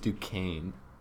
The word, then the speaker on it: Duquesne: It's pronounced Duquesne. – Actually, yeah, I think I've heard. – Duquesne.